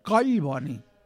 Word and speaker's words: Collboni: Collboni. – Ja està dit. – Sí, com si, els mots compostos es pronuncien com si fossin dos mots. Collboni.